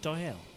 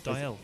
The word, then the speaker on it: Dalziel: But it took until 1832 for a Scottish physician, a guy called John D-A-L-Z-I-E-L. – Isn't that Dial? – Or maybe. – Dial and Pasco. Do you remember that TV program? Dial. I think it's pronounced like they said.